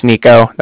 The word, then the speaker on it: Niko: Yeah, like I said, my name's Nico. That's spelled N-I-K-O. – Okay. – And then the Bank of Cardiff is spelled C-A-R-D-I-F-F, as in Frank Frank.